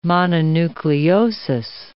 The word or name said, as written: mononucleosis